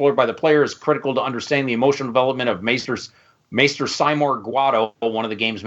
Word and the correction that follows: Seymour: Seymour Guado is his actual pronunciation.